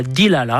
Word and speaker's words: Delilah: Gira, Group Queen, con questa canzone che lui ha chiamato Delilah, magari lo pronuncia Dilala, appena così, a maniera americanesca, ma io, l'americano, una maestra che mica abbastanza.